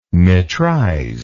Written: metrize

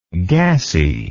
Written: gassiness

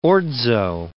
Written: orzo

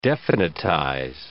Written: definitize

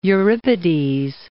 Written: Euripides

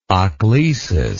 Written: ochlesis

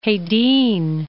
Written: Hedin

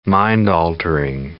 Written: mind-altering

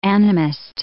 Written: animist

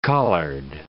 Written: collared

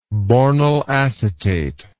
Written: bornyl acetate

